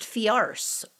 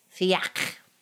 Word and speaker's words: Fiacre: So I looked up how to say his name in French, and it's Fiacre.